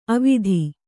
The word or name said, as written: ಅವತ್ತು